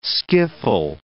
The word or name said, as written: skiffle